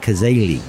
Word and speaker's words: Kazeli: But Lynn, I'm going to try to pronounce it with the accent, Kazeli. – That's right.